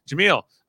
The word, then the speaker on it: Jaemeel: So, Jamil Haysom, which is how you say his name, is our new HOH out of this episode.